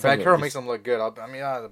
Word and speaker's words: Banchero: Was I the only one disappointed that it wasn't pronounced Banchero? – I think Bankero is pretty goddamn cool, bro. – Yeah, I thought Bankero was solid. – I was disappointed. I thought it was like Banchero.